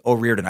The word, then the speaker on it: O'Riordan: And then also number two, O'Riordan, as in Dolores Riordan is pronounced. Oh, okay, O'Riordan.